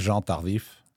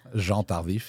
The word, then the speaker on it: Jean Tardif: I say Tardif. That's not right. It's just Tardif, right? – It's, yeah. My name is Jean Tardif.